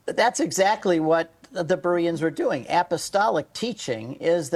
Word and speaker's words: Bereans: So you did answer it in that the Bereans, I think I'm saying that right, the Bereans yes.